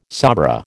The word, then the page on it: sabra: American English